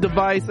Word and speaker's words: DEVIS: Which is not spelled actually device. D-E-V-I-S. Device, device. It's pronounced device.